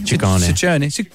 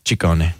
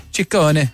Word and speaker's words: Ciccone: Well, in Italian, it works the other way. So the double C's are hard C's and the singular C's are soft C's. – So Ciccione. – Ciccone. – Ciccone.